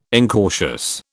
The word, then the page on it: incautious: British English